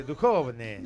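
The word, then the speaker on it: Dujovne: Dujon. ¿Lo dije bien? – Ah, de Duhovne.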